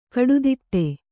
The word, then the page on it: ಕುಡುದಡಿ: kuḍudaḍi